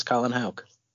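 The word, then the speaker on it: Houck: I've got Hauk. Is that how we're pronouncing it? Right? Colin Hauk? – I believe it is Colin Hauk.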